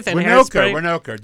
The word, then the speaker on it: Winokur: Winokur.